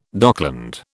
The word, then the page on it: dockland: British English